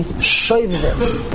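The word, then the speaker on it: Shovavim: Before we see Rashi, we all know that the expression of the weeks of Shevavim, Shevavim, it's pronounced colloquially Shevavim, the weeks starting with Parsha Shemos.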